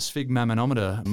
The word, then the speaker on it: sphygmomanometer: But I'm interested in this blood pressure, if they can solve that, because the sphygmomanometer makers of the world are probably getting a little bit nervous. You did well with the pronunciation.